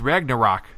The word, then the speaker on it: Ragnarok: Come on, it's Ragnarok.